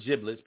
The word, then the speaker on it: giblets: The word giblets, pronounced with a soft G, refers to the hearts, livers, and gizzards of poultry, many chickens and turkeys.